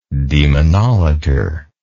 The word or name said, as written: demonolater